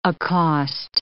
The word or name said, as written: accost